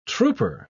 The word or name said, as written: trouper